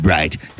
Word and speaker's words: Bright: With all my might, I'll make sure I give it to you like my name is called Bright. That's right. Ladies and germs, it's on sight. Right. Light. Bite. Kite. Right. Everything with rhymes with eyes.